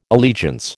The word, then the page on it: allegiance: American English